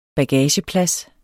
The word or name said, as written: bagageplads